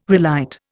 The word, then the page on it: relight: Indian